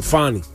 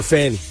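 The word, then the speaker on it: Fani: The way she pronounces it, Fonnie. But most people just call her Fanny.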